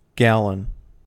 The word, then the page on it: gallon: American English